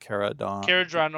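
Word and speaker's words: Kharadron: I'm not a big fan of the Caradhran. How do you say it? – Caradhran Overlords. Yeah. – Caradhran Overlords.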